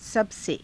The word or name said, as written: sabase